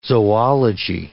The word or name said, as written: zoology